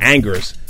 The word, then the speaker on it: Angers: You know, you won the French League MVP for Angers. Am I saying that correctly? The team Angers or Angers. – I know it's not. – You gotta get back to your Quebecois accent.